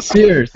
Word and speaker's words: Fiers: Nope, not at all. Wilco Fiers. – Fiers. He's a very fierce guy.